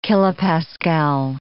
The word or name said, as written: kilopascal